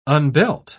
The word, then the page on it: unbelt: British English